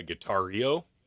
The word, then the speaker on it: Guitareo: Next website, I think it's called, if I pronounce this wrong, I am sorry, Guitario. – Yeah, Guitario.